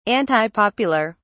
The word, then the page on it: antipopular: British English